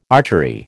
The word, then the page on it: archery: American English